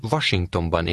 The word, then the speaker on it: Washingtonban: Én Washingtonban élek. Washington. Ban. Washingtonban. Én Washingtonban élek. – Notice that the Hungarian word for Washington starts with a V sound.